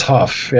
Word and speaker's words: Hough: I believe it's Huff, yeah. – Yeah, it might be H-O-U-G-H, but Huff maybe.